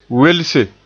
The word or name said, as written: welse